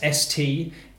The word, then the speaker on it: Steers: And on this part of the call, what I typically do is I overpronounce my own surname like it's an important detail. I try and emphasise the fact that you know me by sort of saying, it's Anthony Steers. That's Steers. Thank you. And I spell it like that and I say thank you at the end.